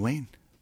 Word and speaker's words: Wain: David Wain, W-A-I-N. – That's easy to remember. – Yeah, rhymes with rain. – Okay. – Like Rain Wilson.